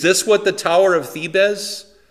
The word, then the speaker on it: Thebes: A Z, yeah, Thebes. It should be two syllables.